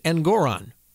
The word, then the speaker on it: Engoron: And that is exactly what happened with Judge Engoron, which rhymes with moron.